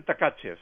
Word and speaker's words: Tkachev: Los rusos lo pronuncian Tkachev.